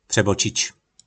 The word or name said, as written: Prebocic